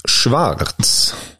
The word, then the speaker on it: Schwarz: Chippen och Schwarz. Eh, bra tyskt uttal där på Stefan Schwarz.